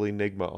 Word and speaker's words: Enygma: My name is Evil Enygma on Twitch.